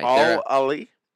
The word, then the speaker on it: Al Ahly: When the schedule was announced, Al Ahly, and I think I'm saying that right. – Al Ahly? – Yep.